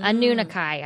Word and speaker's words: Anunnaki: Anunnaki, I've decided. It's A-N-N-U-N-A-K-I. – Look. – Anunnaki. – If Anunnaki wants to come down from their planet and correct us on the pronunciation, they can. But in the meantime, it's Anunnaki.